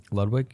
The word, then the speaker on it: Ludwig: Ludwig, yeah.